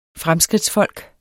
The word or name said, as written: fremskridtsfolk